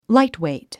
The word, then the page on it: lightweight: láitwèit; ライトウェイト